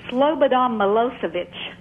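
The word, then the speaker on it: Slobodan Milošević: I can't believe you're going to make me pronounce this, but it's Slobodan Milosevic.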